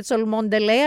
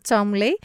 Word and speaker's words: Cholmondeley: Γράφεται όντως, παιδιά, Cholmondeley και προφέρεται Chumley.